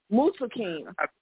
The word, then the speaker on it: Muntaqim: I'm going to give it a try, but I'm going to spell it for you. M-U-N-T-A-Q-I-M. Mutakim. That's what I think is the pronunciation is.